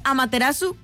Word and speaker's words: Amaterasu: Se escribe Amaterasu, pero se pronuncia Amaterasu, porque la U no la pronuncian los japoneses.